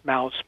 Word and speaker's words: Deadmau5: So he's created visuals for many popular music artists, Depeche Mode, Deadmau5, it's actually pronounced mouse, but it's spelled M-A-U, Vandergram, Diplo, and many others.